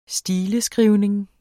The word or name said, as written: stileskrivning